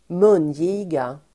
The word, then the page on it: mungiga: ²m'un:ji:ga